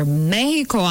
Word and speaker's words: Mexico: Okay, so as South Africa goes against Mexico, or Mexico, actually, that's the correct pronunciation.